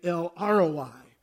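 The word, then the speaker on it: El Roi: And it's pronounced just like it's spelled, L-R-O-I. That's how you pronounce it.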